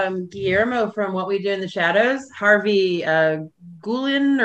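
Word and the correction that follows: Guillén: or I'm not sure how you pronounce his last name. It's Guillen.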